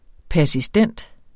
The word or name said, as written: persistent